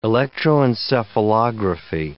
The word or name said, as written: electroencephalography